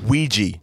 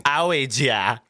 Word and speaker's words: Ouija: How do you say that word? – Ouija. – It's Ouija.